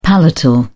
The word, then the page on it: palatal: British English